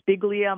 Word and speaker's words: Spigelia: Well, I actually just looked it up on the Missouri Botanical Garden website while you were talking, and it is called Spiglia marilandica, and it's spelled S-P-I-G-E-L-I-A, marilandica.